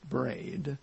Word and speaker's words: Briand: According to her last name is spelled B-R-I-A-N-D, and I believe it's pronounced Braid.